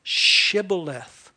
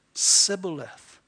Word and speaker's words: Shibboleth: The Gileadites would say it with a hard S, Shibboleth. The Ephraimites could only say it with a soft S, Sibboleth.